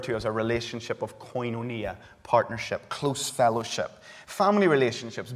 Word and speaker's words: koinonia: The word behind partnership in Greek is a word pronounced koinonia.